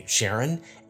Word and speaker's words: Charon: Now, a bunch of you are going to have concerns about the way I say Sharon, and I just want to let you know that I got that pronunciation from Alan Stern, who is the principal investigator of the New Horizons spacecraft. That's the way he says it. He studies it. I just say it the way he says it. So if you have a problem with the pronunciation, take it up with Alan Stern.